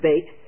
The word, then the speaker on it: bet: I believe the Jews pronounce it bait, but an 8-fold bet.